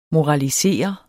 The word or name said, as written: moralisere